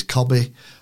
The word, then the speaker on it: Kobbie: He said, it's Kobbie. And I went, as in Cobb. And he went, absolutely spot on.